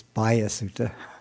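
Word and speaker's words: Bahiya Sutta: And then I want to talk about a discourse called the Bahia Sutra. Actually, I'm sorry, it's Bahia Sutta. Some teachers pronounce it Bahia, but it's an Indian name, so I Googled it, and Google says Bahia.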